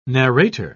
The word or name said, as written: narrator